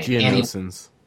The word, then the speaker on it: Geonosians: Geonosians.